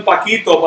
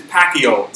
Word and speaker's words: Pacquiao: I always call him Paquito, but my son says it's Pacquiao. So.